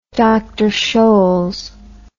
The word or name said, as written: dr scholls